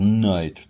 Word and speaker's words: Knight: Фильм называется «Dark Knight» по-английски. То есть слово «рыцарь» оно начинается на букву «K», да. – Но читается оно, да, как «Night», то есть это темная ночь, в общем-то, которая опустилась, да, так сказать.